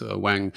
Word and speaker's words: Ng: So he suggested it's NG is pronounced Wang.